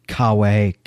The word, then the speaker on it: cave: So, Yes, the, the cave canem, cave in Latin would actually be pronounced cave.